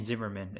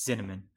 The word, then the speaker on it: Zinnemann: I keep on saying Zimmerman. It's Zinnemann. You know,